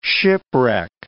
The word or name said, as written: shipwreck